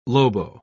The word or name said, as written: lobo